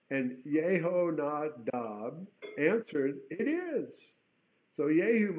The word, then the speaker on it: Yehonadab: Now, when he departed from there, he met this interesting character named Yehonadab. That's how it's phonetically pronounced in Hebrew.